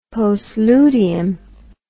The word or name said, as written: postludium